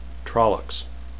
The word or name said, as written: trollocs